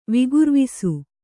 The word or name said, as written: ವಿಗುರ್ವಿಸು